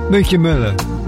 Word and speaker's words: Mica Millar: The lady is Mika Miller, M-I-C-A-M-I-L-L-A-R. The album's called A Little Bit Of Me. That's how she pronounces it.